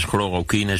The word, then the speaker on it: chloroquine: nog ingewikkelder Het voorvoegsel kun je gewoon weglaten. Dus chloroquine, zo noemen medicijn mensen het ook.